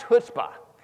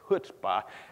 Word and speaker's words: chutzpah: By the way, you got to say this right. I know it's spelled chutzpah. It's not how you say it. It's chutzpah.